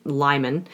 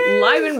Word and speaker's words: Lyman: What do we think? Or should it rhyme with Hyman? – Lyman Baum. – Lyman Baum.